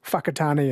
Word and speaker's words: Whakatane: I think the WH is pronounced F, like Whakatane is WH, Whanganui WH instead of F.